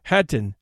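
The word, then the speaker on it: Khatyn: Hatyn, in which the KH is pronounced like the English H, is a small village some 30 miles to the northeast of Minsk, the capital of Belarusia.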